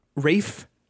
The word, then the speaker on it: Ralph: Rafe Fiennes. – You know what? It's spelled Ralph. – Is it? It's got the L? – It's I'm not saying you're wrong.